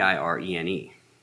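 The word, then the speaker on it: Irene (εἰρήνη): the Greek word is spelled just like Irene. I think it's spelled just like Irene, E I R E N E, but it's pronounced Irene.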